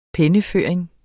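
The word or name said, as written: penneføring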